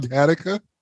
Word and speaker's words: Gattaca: I'm like, what is going on, Gattaca? Oh, my God. – Oh, shit. Gattaca rhymes with Africa.